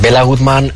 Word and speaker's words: Béla Guttmann: Hablamos en la introducción la de Bela Goodman. ¿Quién es? No sé si se dice así. ¿Quién era este personaje? – Pues Béla Guttmann fue un entrenador que nació en el año 1899 en Budapest.